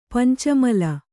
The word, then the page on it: ಪಂಚಾಮ್ಲ: Kannada